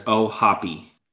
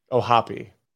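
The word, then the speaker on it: O'Hoppe: Reference has the pronunciation as Ohapi. – Ohapi.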